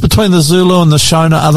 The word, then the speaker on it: Shona: Shona, yes.